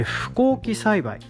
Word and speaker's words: 不耕起栽培: で不耕起栽培、漢字は、不可能の不に、耕起、耕すに起こすと書いて不耕起栽培と読みます。